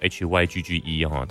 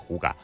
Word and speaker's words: hygge: 它其实写作 HYGGE，它不叫 Hygge，它在丹麦那边念作呼嘎。